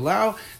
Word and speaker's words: Lao: Her name, by the way, spelled A-J-A. My last name, as well as hers, is spelled L-A-O, just like the country, without the S yes.